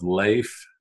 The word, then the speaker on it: Lafe: My parents thought that they would do an Americanized version of Leif, and it gets butchered much more than had they kept the whatever Norwegian spelling. So, find me, Leif Bailey, LinkedIn. – L-A-F-E, Leif, yep. Bailey, B-A-I-L-E-Y, on LinkedIn.